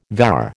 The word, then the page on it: vair: American English